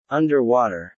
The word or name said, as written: underwater